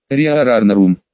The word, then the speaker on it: Риа Рарнарум: Имя есть, Риа Рарнарум, ударение на все А.